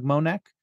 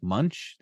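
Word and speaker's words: Moench: I pronounced it as Doug Monek when it's pronounced more like Munch. The N isn't in the place where it would sound like you have pronounced it.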